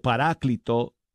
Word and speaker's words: Paráclito: Es un cantautor colombiano, Freddy Chavarría, y su canción se llama Paraclito o Paráclito, perdón, lo dije mal, Paráclito Divino, aquí está.